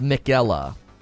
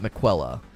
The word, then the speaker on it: Miquella: Oh, it's pronounced Miquella, not McQuella.